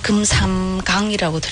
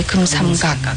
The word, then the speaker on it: 금삼각: 여기서 발음 들을 때는 금삼강이라고 들리거든요. – 아, 각. – 네, 금삼각. – 금삼각. – 네. 네, 금삼각 눈부신. – 금삼. – 에, 자그 금삼강 눈부신 이렇게 들려요. – 어. – 네, 여기서 금삼각. 발음 조심하시고요.